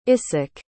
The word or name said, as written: issac